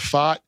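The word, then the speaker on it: Pfaadt: Fott, who's given them now a good number. – So that's how you pronounce his last name.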